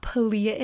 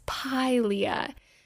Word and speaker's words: Pilea: I was saying it wrong. It's not Pilea, it's Pilea.